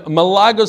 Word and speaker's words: Malaga: Malaka. – Malaga. So is that wine also Malaka? Kedem Malaka? Malaga. No, that's Malaga.